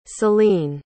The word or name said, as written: celine